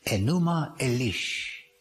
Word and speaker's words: Enuma Elish: This is how they pronounce it, when above, Enuma Elish, when above, everything comes from above.